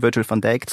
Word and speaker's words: Virgil van Dijk: Und beim FC Liverpool kehrt wiederum Virgil van Dijk zurück, der ja ein sehr guter Innenverteidiger ist.